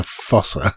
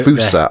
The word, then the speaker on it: fossa: but it's a fossa. So yeah, a fossa, I think it's supposed to be pronounced. Fossa, I'm reliably informed by my daughter, who is a one-time Madagascar cartoon fan.